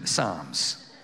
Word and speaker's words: Psalms: I love the book of Psalms because it's spelt with a P. So it's Psalms. But we don't, it's like a silent P, which is hard in the middle of the night, but that's a really bad joke.